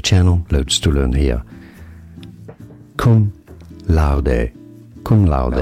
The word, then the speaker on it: cum laude: It's not cum cum lord. It's cum laude.